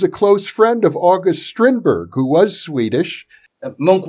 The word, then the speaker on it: Strindberg: And in the Occult Diary, I, I used Strindberg, which is how they pronounce his name here,